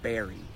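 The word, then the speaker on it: Barre: This is pronounced Barry.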